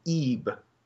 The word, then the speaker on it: Ib: It's called Eeb. It's spelled IB.